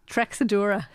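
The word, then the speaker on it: Treixadura: Trexadura. – Oh, right, okay. – Okay, because that's how we say it. – Okay. – Say it for me again. – Trechadura. – No, Trexadura.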